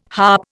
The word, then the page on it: harp: Indian